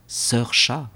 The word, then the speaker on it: Saoirse: Il répéta le nom bizarre dont la prononciation ressemblait à Niva, puis celui de la sœur, Saoirse, qui se prononçait Saoirse, un peu comme le début du mot cerise et le début du mot chat.